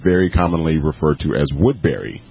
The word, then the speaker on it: Woodbury: Yeah, some people, you know, it's correctly pronounced Woodbury, but sometimes some people will call it Woodbury.